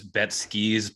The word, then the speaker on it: Betskies: Did I say that right? And just want to make sure everyone knows where they can find you. – Yeah, it is Betskies plural because unfortunately someone else had the Betsky Twitter handle, but we're rolling with it.